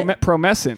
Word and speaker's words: Promescent: Promescent. – Promescent. – So okay. – This is my live read that I'm giving it to you. And so if anyone sorry. Wants to buy this Promescent,